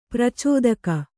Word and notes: ಪ್ರಚೋದಕ: pracōdaka

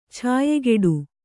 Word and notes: ಛಾಯೆಗೆಡು: chāygeḍu

